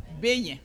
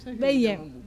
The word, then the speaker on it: beignet: B-E-I-G-G-N-E-T. Beignet. – Beignet. – B-E-I-G-N-E-T.